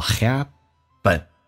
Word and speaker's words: happen: 面以 z, 结尾 that 以 th 开头，那么这个 z 就可以击穿 th 音。你当然你可以不用进行击穿，叫 things that, 你可以这么说，你也可以击穿变成 things that happen 这个单词，两个元音中间所加的清辅音要被浊化，叫 happen.